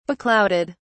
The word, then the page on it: beclouded: British English